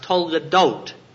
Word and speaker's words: toledot: The word translated generations in Hebrew is pronounced toledot, and it means stories about man.